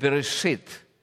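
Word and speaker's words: «берешит» (בראשית): Вот, оно читается как «берешит», по-еврейски «начало».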